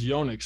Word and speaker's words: Yonex: And so, yeah, it is, like you said, named after him, and it is Yonex. So I was corrected this morning, and I didn't know that. So now I feel like I'm a much more educated, sophisticated tennis fan now that I can pronounce it correctly.